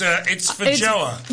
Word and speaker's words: feijoa: Okay, well, it's feijoa. – It's better than I thought it would be. – And it's spelled F-E-I-J-O-A-S, feijoa.